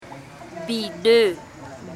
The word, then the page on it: bido: Embera Chamí